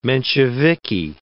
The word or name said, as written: Mensheviki